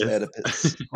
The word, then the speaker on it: Oedipus: Why is there an O there? – Oedipus. – Oedipus. – It's Oedipus. – Holy shit, there really were dinosaurs. – I'm like, what kind of sick person puts a silent O at the beginning of a word? – Ask his parents.